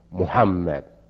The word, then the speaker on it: Mohammed: And you perhaps have recognized that I use that spelling, M-O-H-A-M-M-E-D. I use that spelling too. And how is it pronounced? Muhammad. How did the Americans pronounce it back then? Mohammed.